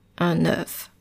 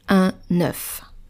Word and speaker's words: œuf: Well, you're going to pronounce it E. E, un cœur, un œuf. Voilà. So make sure to write this one with the O and the E, the ligature. And make sure to pronounce it un œuf. The next word, joyeux, joyeux, J-O-Y-E-U-X, joyeux.